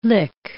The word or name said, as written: lick